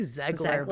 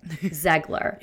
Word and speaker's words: Zegler: I thought that him and Rachel Ziegler, is that how you say her name? – I say Zegler, but only because there's no I.